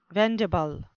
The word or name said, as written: vendible